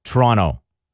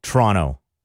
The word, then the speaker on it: Toronto: But as my friend from Toronto would say, that's not how you say Toronto. In fact, he says that even if you don't pronounce the second T, it's still wrong. He says you got to slur it all together. Toronto. Like TR, Toronto. That's how he says it.